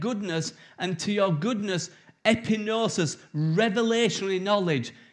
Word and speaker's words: epignosis: It's epignosis, but the G is silent, so it's epinosis.